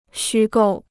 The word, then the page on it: 虚构: xū gòu